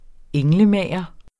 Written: ENGLEMAGER

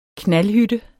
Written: knaldhytte

